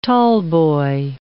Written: tallboy

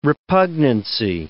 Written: repugnancy

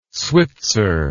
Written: switzer

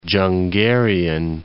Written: Dzungarian Basin

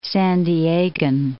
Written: San Diegan

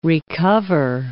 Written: re-cover

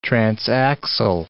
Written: transaxle

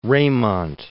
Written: Reymont

